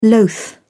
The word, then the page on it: loath: British English